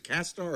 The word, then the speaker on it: castAR: Now, people who contribute to the Kickstarter project, will they get a pair of Cast AR glasses? Am I saying it right? Is it Castar or Cast AR? – Cast AR.